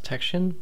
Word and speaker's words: Texian: Anyway, Fort Tehran was a former Mexican military post and Texican settlement located in Tyler County in East Texas. – I think that's pronounced Texian.